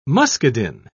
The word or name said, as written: muscadine